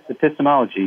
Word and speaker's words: epistemology: I guess it's, I have never even pronounced it, epistemology, is that right? – Oh, yes, epistemology, yes.